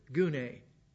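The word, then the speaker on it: Gune (γυνή): Doesn't sound very feminine, but I'll pronounce it for you. Gune.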